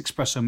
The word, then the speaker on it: espresso: But how much are you spending on this espresso machine, Bebs? – I'm going to refuse to answer your question until you pronounce the word correctly. – DeLonghi. – Not that word. – Espresso. – There's no X.